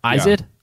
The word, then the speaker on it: Izod: That's that underwear brand that I-Z-O-D? – They sell. Yeah, at Walmart. Izod? Yeah, Izod. – I-Z-O-D.